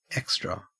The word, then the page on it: extra: Australian English